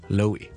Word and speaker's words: Chloe: 咁啊，其实咧好简单嘅啫，尝试读翻个 L O E 先，我哋就咁读成 Chloe。 OK，跟住前面好轻轻地加个 k 咁嘅音就得啦，Chloe。